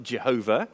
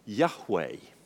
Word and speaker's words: Yahweh: And from medieval times, the word behind this word Lord in capitals was thought to be pronounced something like Jehovah. So we have old hymns that talk about Jehovah, and also there's a sect called the Jehovah's Witnesses. But that was now realized to be a mistake. And more likely, this name would have been pronounced something like Yahweh.